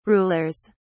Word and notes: rulers: British English

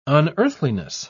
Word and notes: unearthliness: British English

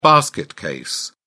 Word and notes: basket case: British English

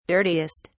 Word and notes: dirtiest: British English

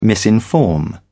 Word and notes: misinform: British English

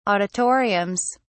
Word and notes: auditoriums: British English

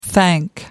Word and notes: thank: American English

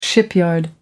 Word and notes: shipyard: American English

